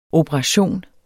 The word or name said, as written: OPERATIONERNES